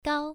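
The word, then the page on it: gao: tone 1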